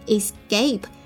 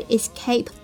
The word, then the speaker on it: escape: 那字母 C 呢，我们知道可以发 k 的音，但是在这个单词里呢，它要浊化读成 escape，不是 escape， OK， escape 表示逃走逃脱， escape，比如说 the thief has escaped，就是这个小偷逃走了， the thief has escaped。